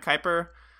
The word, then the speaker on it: Kuyper: I might have mispronounced that. Now that I think about it, I've only heard his name ever, or I've only seen his name written, not read, but Abraham Kuyper, you know? – Yeah, that's a big word.